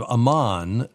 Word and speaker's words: Amon: It's spelled A-M-O-N. – A-M-O-N. I've never seen that. Or I've seen it, and I've thought of Amon, but that's obviously not how to say it. Yeah. – Well, you can pronounce it Amon, but I pronounce it Amon.